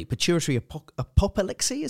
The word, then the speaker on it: pituitary apoplexy: But, you know, to have a bleed on the brain, the pituitary, I can't even say that properly, pituitary apoplexy, is that right? – Yeah.